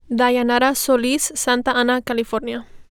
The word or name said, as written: Dayanara Solis